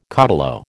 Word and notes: caudillo: American English

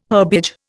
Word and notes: herbage: Indian

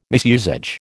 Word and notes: misusage: American English

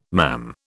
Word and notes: mam: British English

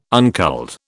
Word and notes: unculled: British English

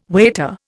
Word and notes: waiter: Indian

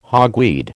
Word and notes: hogweed: American English

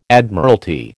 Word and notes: Admiralty: American English

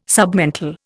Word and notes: submental: Indian

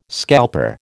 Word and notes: scalper: American English

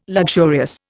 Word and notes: luxurious: Indian

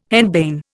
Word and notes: henbane: Indian

